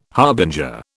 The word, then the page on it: harbinger: British English